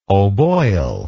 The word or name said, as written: oboyle